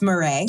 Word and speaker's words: Marey: Uh, Etienne Yules Murray. It's Murray.